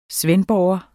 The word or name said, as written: svendborgenser